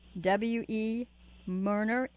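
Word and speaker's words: W. E. Moerner: Professor Mirner's last name is spelled M-O-E-R-N-E-R, W.E. Mirner, M-O-E-R-N-E-R.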